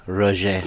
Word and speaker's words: Roger: You didn't say that right. It's Roger. – Roger. Okay. Roger, if you listen to this, I'm sorry. Yeah. I'm a Texan. I'm not very good with French.